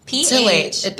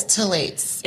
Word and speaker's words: phthalate: It's phthalate. The pH is silent.